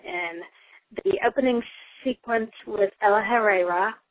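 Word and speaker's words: El-ahrairah: Yes, that's how you pronounce his name, which I only know because it was in the Mark's Down book, which I don't recommend so much. It wasn't very good. That's El Ahreira, which I pronounced completely wrong in the movie.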